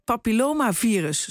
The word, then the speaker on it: papillomavirus: dat is de afkorting voor het humaan humaan papillomavirus, zo moet je het uitspreken, die daardoor kunnen ontstaan.